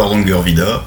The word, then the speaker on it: Hardangervidda: D'abord, est-ce que je l'ai bien prononcé? – C'est ça, c'est bien le Hardangervidda et c'est bien en Norvège, donc au sud de la Norvège.